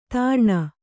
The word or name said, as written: ತೀರ್ಣ